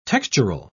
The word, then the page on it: textural: British English